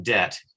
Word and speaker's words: debt: And they noticed, for example, debt, D-E-B-T. They thought, well, that comes from debit, D-E-B-I-T. So let's remind everybody, and let's put the B in there so we spell it D-E-B-T, okay? Because for generations, it was just spelled D-E-T.